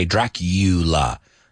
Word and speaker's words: Dracula: There's another one that we pronounce differently. No, Dracula. We say Dracula. The U is like a U, como tú.